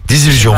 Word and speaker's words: désillusion: Là, c'est une délusion, une délusion, ça se dit? – Désillusion, voilà. – Oui. – J'ai oublié une syllabe.